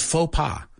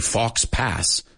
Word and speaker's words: faux pas: So this one is faux pas. Hey, at least we don't say fox pass.